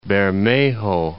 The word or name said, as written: Bermejo